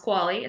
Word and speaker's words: Qvale: Our last name is spelled Q-V-A-L-E, but it's pronounced Qualley. It's Norwegian.